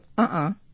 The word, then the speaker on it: uh-uh: If you listen to uh-uh, it's equal. So uh-uh is more staccato.